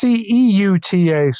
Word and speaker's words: Ceuta: But, So, I were talking before we began recording, and he mentioned what's going on in Quetta, which is a. Am I pronouncing it correctly? – I think it's CEUTA, so it's Ceuta or.